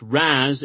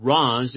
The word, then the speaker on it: Ra's: Pronounced Raz and Roz in Batman Begins and The Dark Knight Rises, Ra's in Batman the Animated Series and by creator Denis O'Neil.